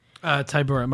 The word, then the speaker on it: Tybura: How do you say that guy's last name? – Tybura, Marcin Tybura.